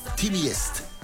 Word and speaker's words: Teamgeist: En el 2006 estaba el Teamgeist, Teamiest. Más bien, esa es la pronunciación correcta.